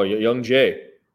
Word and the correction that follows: Jung: I think that's pronounced Jung, by the way. – Young Jay. – No, Jung. Jung Jay.